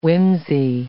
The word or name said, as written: whimsy